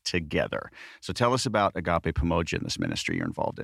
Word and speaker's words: Agape Pamoja: So Agape Pamoja, first of all, thanks for pronouncing it correctly. I can't begin to tell you how many times I go to a store and they see us on tax exempt and they go, Agape Pamoja.